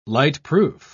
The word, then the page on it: lightproof: British English